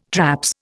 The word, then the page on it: traps: Indian